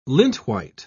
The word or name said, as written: lintwhite